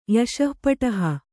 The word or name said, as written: ಯಶಃಪಟಹ